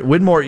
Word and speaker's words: Winmau: And if I want to buy the board, Winmore, it sounds like Win More, which sounds like a great, I want to win more. It's spelled W-I-N-M-A-U, right? – Yes.